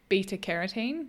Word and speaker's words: beta carotene: So orange, carrot, sweet potato, because when you eat the orange foods, they contain beta carotene. Mm-hmm. Is that how you say it? – Yeah.